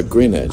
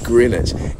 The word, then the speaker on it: Greenwich: Greenwich or Greenwich? – No, Greenwich. – Oh. I know. – It's Greenwich. And isn't Greenwich where the Wombles come from?